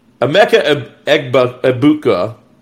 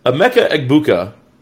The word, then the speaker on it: Emeka Egbuka: Yeah, Emeka Egbuka. I'm sorry, I totally butchered that. Let's try that again. Emeka Egbuka is the other guy opposite of Marvin.